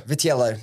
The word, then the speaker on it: Vitiello: Vitiello.